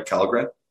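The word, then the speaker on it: Källgren: So I hope, you know, moving forward with, it's Kalgren, right? Am I pronouncing that right? Kalgren? – Yep.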